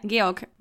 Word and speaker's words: Georg: because Georg, is that how you would pronounce that in German? – Yeah, Georg.